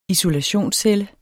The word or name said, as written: isolationscelle